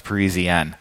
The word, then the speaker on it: Parisienne: We called it a Parisienne for a long time until somebody corrected us. And I think he said, no, it's Parisienne.